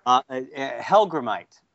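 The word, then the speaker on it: Hellgrammite: Did I pronounce that right? – No. – Oh God. – It's all right. I didn't know how to pronounce it at first either, and nor did most of my friends, and the general population. I think Hellgramite. – Oh, Hellgramite. Okay. – Yeah. Is how you pronounce that guy.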